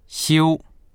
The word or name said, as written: xiu1